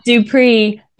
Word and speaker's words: DuPrez: And it's DuPrez, but with a silent Z. – Yes. Yes. Just like the terrible movie that's out there, You, Me, and DuPrez. Do not recommend. But yes, that is how you say our last name. The Z is silent. It's French.